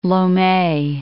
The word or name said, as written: Lome